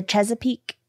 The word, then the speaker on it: Chesapeake: Three separate dark green Kenneth Cole suitcase- suitcases have been discovered around the Chesapeake Bay.